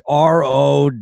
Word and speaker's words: Rowlf: Although Rolf, how do you say it? Ralph Rolf. It was Rowlf.